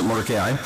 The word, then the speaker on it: Mordecai: Now, I mentioned Moses, and I mentioned, I pronounce it Mordecai, I mentioned those two figures.